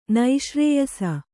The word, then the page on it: ನಿಶ್ರೇಯಸ: niśrēyasa